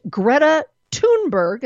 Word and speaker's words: Greta Thunberg: And that has to do with yesterday at the UN, a 16-year-old Swedish girl with a long braid named Greta Thunberg, and that's how you say her name. I looked it up.